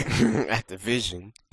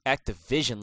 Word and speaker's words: Activision: So Wes and Zampella filed a lawsuit against Activision. Wow, I put the stress on the wrong syllable there. – Activision. – Activision, like you say, umbrella, insurance.